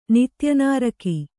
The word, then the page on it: ನಿತ್ಯನಾರಕಿ: kannada